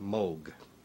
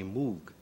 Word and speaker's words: Moog: In Germany, it's a fairly common name, and there it's pronounced moog. In Great Britain, English-speaking countries, people see the double O and they say moog.